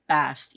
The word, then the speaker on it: Bastet: So there's kind of a problem with that in that at a certain point from what I read, the ancient Egyptians didn't pronounce the ending T. So it had just been bis. But that wasn't really her name. So people added on the tet at the end to get you to try to pronounce the T at the end. So that became Bast, even though it was spelled Bastet.